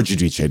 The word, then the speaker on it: Giudice: But it's a full Teresa Giudice situation, or Giudice, depending on which era of Real Housewives you watch and how she's pronouncing her last name that day.